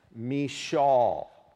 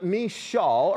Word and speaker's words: Michal: We're studying today the story of David and his wife, Michal. Now, unusual name, right? Not one we're accustomed to. But Michal, or as the Hebrew word truly is to be pronounced, Michal, like that.